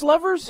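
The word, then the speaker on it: Luvvers: Now, your first few singles are credited to Lulu and the Louvers. – Lovers. – It's pronounced Lovers? – Lovers, yes. Okay. I know it's a weird spelling.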